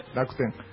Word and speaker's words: Rakuten: Ahí está, Rakuten. Rakuten, ¿no? ¿Cómo se dice? – Rakuten.